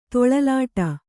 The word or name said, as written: ತೊಳಲಾಡು